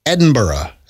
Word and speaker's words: Edinburgh: Now she is in Edinburgh.